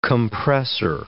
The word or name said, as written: compressor